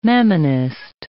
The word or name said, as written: mammonist